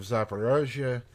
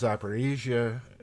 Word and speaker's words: Zaporizhzhia: And I'm obviously using the Russian pronunciation of Zaporizhia. The Ukrainian is Zaporizhia, basically not much of a difference.